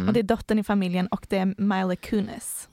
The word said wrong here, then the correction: Mila Kunis: Mila Kunis kanske uttalas. – Milan. – Mila. Nej, bara Mila. Kunis.